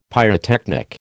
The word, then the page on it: pyrotechnic: American English